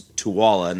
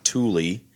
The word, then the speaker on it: Tooele: It's Tooele, not Thule. – I like calling it Thule. – It was Thule, but it's pronounced Tooele.